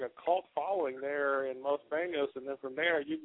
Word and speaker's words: Los Banos: I've been following you for a while, and I know you're up there from, I guess you call it, the pronunciation is Los Banos, right? – Los Banos, yeah.